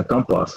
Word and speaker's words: компас: Он потом вот, или топор, помните, знаменитый там произведение, как «Дети капитана Гранта», по-моему, да, когда там под компас, правильно, так ударение ставится на корабле, все-таки не компас, а компас, вот,